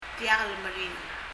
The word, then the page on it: diak lmeringel: [ðiək (l)mɛriŋɛl]